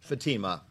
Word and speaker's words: Fatima: You're close. It's Fatima. – Fatima.